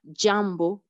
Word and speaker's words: jambo: Par exemple, ici, on dit jambo comme dans adjectif.